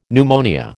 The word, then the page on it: pneumonia: American English